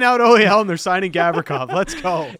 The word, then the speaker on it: Gavrikov: Whose Gavrikov. Last name rhymes with Mavrikov.